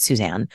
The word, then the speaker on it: Suzanne: It's Suzanne, and not all the things that people say.